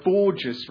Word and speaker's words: Borges: I asked her how to pronounce her name after this poem, and she said it's Borges. Sarah Borges rhymes with gorgeous.